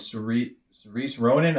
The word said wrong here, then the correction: Saoirse Ronan: I always butcher her name. But Saoirse Ronan.